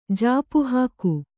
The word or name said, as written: ಜಾಪುಹಾಕು